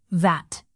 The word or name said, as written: vat